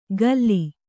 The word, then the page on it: ಕಲ್ಲಿ: kannada